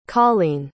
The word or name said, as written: karlene